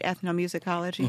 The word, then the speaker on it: ethnomusicology: So I pronounced that right, ethnomusicology? – Mm-hmm.